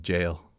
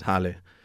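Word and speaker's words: jale: jail. So it's J-A-L-E, which in Spanish is Halle, but in English it would read jail with a long A.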